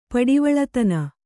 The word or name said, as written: ಪಡಿವಳತನ